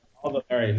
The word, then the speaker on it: Valéry: Yeah, Paul Valéry.